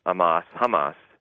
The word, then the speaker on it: Hamas: And that after that had happened, Hamas, with silent H, the anti-Zionist Palestinian faction, I say, would be the power in the Palestinian state.